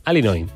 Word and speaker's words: Illinois: Se pronuncia Illinois. – Sí, perdón, sí. – Illinois. – Pues yo digo Illinois, si no entendemos todos. Porque como diga Illinois, nadie lo entiende.